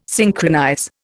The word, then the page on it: synchronize: Indian